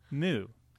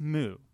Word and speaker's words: MØ: It's it's it's, it's it moo. I think it's moo.